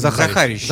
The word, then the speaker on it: Захарищев: На радио Фонтанка FM группа Оберманекен, а именно Анджей Захарищев, фон Брауш. А почему такое имя? – Ну, во-первых, тут немножко ударение исправить, тогда будет понятнее. – Захарищев. Захарищев, да. Простите, пожалуйста.